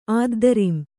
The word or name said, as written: ಆದ್ದರಿಂ